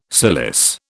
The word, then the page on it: cilice: British English